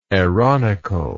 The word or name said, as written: aaronical